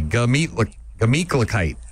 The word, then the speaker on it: Gemütlichkeit: Here we go. – Meat. Meat. – Kite. – Kite. Glamit. – Not a gla. Just G-A. Gemütlichkeit.